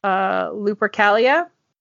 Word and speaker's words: Lupercalia: And on February 14th, in line with, how did I pronounce that? Lupercalia? – Yes.